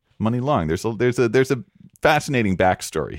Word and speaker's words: Muni: Money Long, spelled M-U-N-I, like the San Francisco Public Transportation Authority, Muni, but pronounced money.